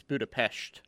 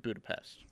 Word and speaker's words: Budapest: And it's Budapest, not Budapest.